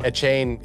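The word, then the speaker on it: Achane: A chain says EJ Will McClay. Pronounced A chain, I believe.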